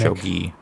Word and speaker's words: shogi: She played Shoji, right? – Yeah. – Yeah. So like they could have like some kind of Shoji mechanic. – Shogi. – I don't remember how to pronounce. Apologies. – I think Shoji is a name. Shogi. Oh, I don't remember is the game. – Shogi. Yeah. Shogi. Yeah. There we are. That's correct.